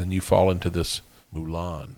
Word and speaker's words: Moulin: I think it's like Moulin Rouge. It's probably all right, we'll call it that.